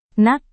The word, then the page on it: nak: Indonesian